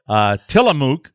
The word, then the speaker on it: Tillamook: Tillamook, did I pronounce that correctly? – Yeah, it's a great brand.